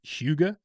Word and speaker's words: hygge: Like I mispronounced yesterday, but it's hygge.